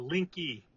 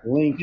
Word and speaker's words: Linke: It's Linke. – Linke. I thought it was Link, but it's Linke, right? – Linke. Yeah, most people think the E is silent, but yeah, it's Linke.